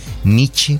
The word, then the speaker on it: Nietzsche: Nietzsche, como el grupo, ¿no? – Pero como el grupo. Como el grupo de salsa. como el grupo de salsa. Nietzsche.